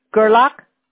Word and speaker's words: Gerlach: G-R-L-A-C-H. – G-R-L-A-C-H? – No, G-E-R-L-A-C-H. – Oh, G-E-R. Okay. Gerlach?